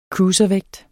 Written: cruiservægt

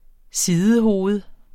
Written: SIDEHOVED

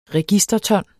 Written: registerton

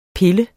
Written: pil

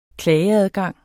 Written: klageadgang

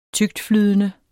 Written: tyktflydende

